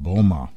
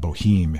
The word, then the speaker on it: Boehme: said the German cobbler mystic Jacob Boehme. or Jacob Boheme if you were going to pronounce it in an, an Americanized or anglicized sort of way.